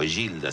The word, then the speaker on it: Egilda: Y su madre, también italiana, Egilda, o Gilda se pronunciaría, La Fagna, que tuvo 9 hijos.